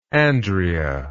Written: Andrea

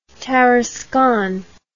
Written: Tarascon

